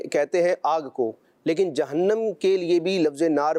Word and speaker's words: جہنم: اس کو لوگ جہنم بھی پڑھتے ہیں، لیکن اس کا درست تلفظ جہنم ہے۔